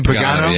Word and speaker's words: Pagano: So, Pagano, is that how you pronounce the name? – Pagano, yeah.